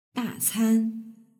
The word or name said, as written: dacan